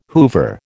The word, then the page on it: Hoover: American English